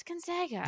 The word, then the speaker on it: Gonzaga: What is it, by the way? – It's Gonzaga. – Gonzaga.